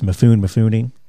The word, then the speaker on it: Mifune: I never know if I'm saying that right, if it's Mifune.